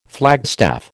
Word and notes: flagstaff: American English

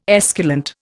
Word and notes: esculent: Indian

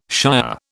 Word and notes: shire: British English

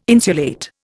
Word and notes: insulate: Indian

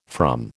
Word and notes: from: American English

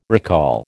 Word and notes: recall: American English

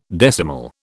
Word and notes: decimal: British English

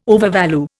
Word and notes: overvalue: Indian

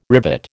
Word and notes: rivet: American English

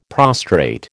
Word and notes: prostrate: American English

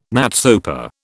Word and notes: NATSOPA: British English

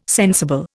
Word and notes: sensible: Indian

